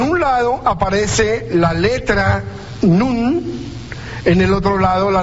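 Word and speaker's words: Nun (נון): La N, o sea, no es que aparezca el nombre Nun, sino la letra N que se pronuncia Nun, la G, la H y la S.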